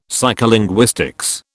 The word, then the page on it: psycholinguistics: British English